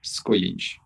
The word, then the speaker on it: Skujins: Skujins. I'm missing a syllable in there. Got to add an extra I. – Yeah. – Yeah. – It's J-I. Yeah. J is a year and Okay. – At the end.